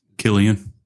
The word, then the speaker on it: Cillian: Killian Murphy, I guess is how you say it, right? – Killian.